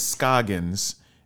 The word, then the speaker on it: Scoggins: Well, so my last name is Scoggins, and I figured drunk guys from South Arkansas could not pronounce Scoggins. That's too many syllables.